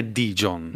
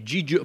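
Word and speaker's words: Gijón: Che non è Dijon. – No, è Gijon.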